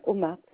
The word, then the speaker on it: Omar: Omar, M-A-R pour mon nom, et prénom, je veux dire. – O-M-A-R. – O-M-A-R. Omar. – Omar. Omar, le nom de famille, c'est ça? – Voilà, voilà, tout simplement Omar, O-M-A-R. – Ok, et le prénom? – Zoulira, Z-O-U-L-I-R-A. – Z-O-U-L-I-R-A, si ça se prononce.